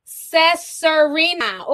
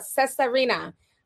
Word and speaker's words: Cesarina: Pronounce Cesarina. Ooh, okay. That's pretty. I wouldn't have thought it was pronounced like that, the way it's spelled, Cesarina.